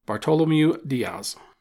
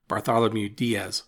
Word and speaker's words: Bartolomeu Dias: I want to start out by saying that Diaz is often referred to in his anglicized pronunciation, Bartholomew Diaz. I'm going to try and stick to the Portuguese pronunciation, but know that I grew up calling the guy Bartholomew, so forgive me if I mess up the name during the podcast.